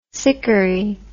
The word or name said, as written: sikuri